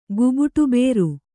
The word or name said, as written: ಗುಬುಟುಬೇರು